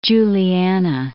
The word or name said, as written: Juliana